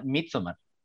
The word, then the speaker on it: Midsommar: Él lo pronuncia Midsommar. Entonces, pues como él es el director, así lo vamos a, decir, ¿no?